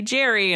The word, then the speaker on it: Geri: I've given up at Starbucks because it's G-E-R-I and I say Jerry and I'm just like, What's the worst Starbucks mishap you've ever had? – Oh, with my name?